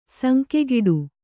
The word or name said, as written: ಸಂಕೆಗಿಡು